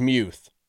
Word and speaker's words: Freiermuth: Sometimes Pat Freiermuth, depending on how you want to pronounce that, I should say. – It actually is Muth. But he really doesn't care. And it's also easier for everybody to just say Muth.